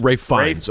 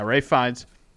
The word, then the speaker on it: Ralph Fiennes: I think it's Rafe Fine. Okay, Fiennes. It's Rafe Fiennes. Okay. – Okay, so yeah, Rafe Fiennes. Spelled the same way, R-A-L-P-H.